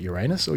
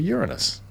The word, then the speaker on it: Uranus: You either pronounce it Uranus or Uranus. And I think the latter is more sort of American English, I think.